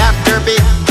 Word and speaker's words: afterbeat: beat or a beatnik beat, but the afterbeat. The emphasis is on the last bit, that the afterbeat.